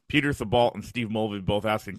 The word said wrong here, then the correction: Thibault: Tebow. Peter Tebow.